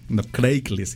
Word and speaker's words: Craigslist: No Craigslist, se dice Craigslist. Craig. Como si fuera Greg, pero con C.